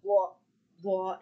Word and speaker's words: Blois: His eldest brother was William, who would have been the Count of Blois and Charles. – You want to say Blois, don't you? – I just want to go Blois. I don't want to pronounce the S.